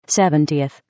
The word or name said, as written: 70th